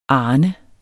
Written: arne

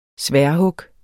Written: sværdhug

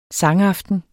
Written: sangaften

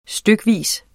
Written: stykvis

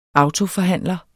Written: AUTOFORHANDLER

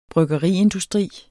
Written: bryggeriindustri

